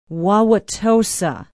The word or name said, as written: Wauwatosa